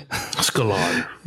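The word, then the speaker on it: Sklar: I mentioned earlier the Marty Skyer. You said how I say the name correctly. – Skalar. – Yeah. – Oh, Skalar. – Where's the L? Okay. Anyway, I forgot the L, I guess.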